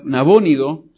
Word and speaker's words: Nabónido: Y probablemente ya sabía que su padre, que Nabónido, se pronuncia en español,